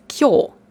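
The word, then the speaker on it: Kyo (今日): Und wenn man es in Kanji schreibt, dann spricht man es aber nicht Konnichi aus, sondern Kyo. Kyo heißt heute.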